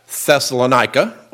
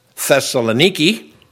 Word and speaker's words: Thessaloniki: Well, the Thessalonian letter is a letter to the church at Thessalonica, or if you were pronouncing it correctly in Greece, Thessaloniki, but since we're English and English obviously rules the world, we'll pronounce it that way.